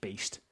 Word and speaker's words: Baest: Although it's spelt B-A-E-S-T. So to me, that sounds based, but it's the Danish death metal band, so I'm assuming it's just Beast.